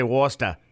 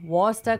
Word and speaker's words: Worcester: Some people say Worcester. Worcester sauce. – Oh, I'm going to start using that. Worcester. But then it sounds like rooster.